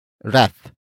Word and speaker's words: ref: And that object is ref. Ref. Ref. Ref is spelled as R-E-F.